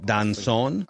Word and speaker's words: Doncon: I believe it's pronounced Rain Donchon, D-O-N-C-O-N. – Donchon, I think. Yep, Donchon. – I think you're right.